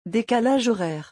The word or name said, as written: decalage horaire